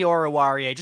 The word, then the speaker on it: Oruwariye: Oh, Amani Oruwariye.